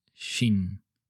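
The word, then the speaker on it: shin (心): Utilizan un solo ideograma, que en japonés se pronuncia shin.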